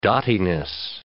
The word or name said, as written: dottiness